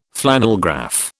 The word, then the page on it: flannelgraph: British English